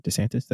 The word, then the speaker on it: DeSantis: Make sure I'm pronouncing that right. DeSantis. That sounds right.